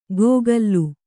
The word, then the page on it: ಗೋಗಲ್ಲು: gōgallu